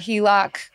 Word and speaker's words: HELOC: I recently got approved for a HELOC. Is that how you pronounce it? – That is how you pronounce it.